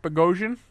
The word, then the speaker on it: Bogosian: Brought in Evander Kane and Zach Bogosian.